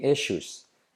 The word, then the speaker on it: issues: There won't be any issues. S, la doble S es como shh, issues.